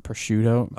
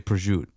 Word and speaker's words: prosciutto: Is that how you say it? – No. Prosciutto. – Prosciutto. Prosciutto. No. Is it with an SH sound? – It's like how he said.